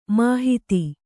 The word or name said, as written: ಮಾಹತ್ತು